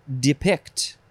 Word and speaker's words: depict: The next word is depict. First syllable can be de or d.